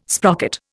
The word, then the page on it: sprocket: Indian